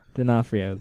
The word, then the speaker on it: D'Onofrio: How old is Vincent D'Onofrio? – D'Onofrio. – First O is silent. Let it go.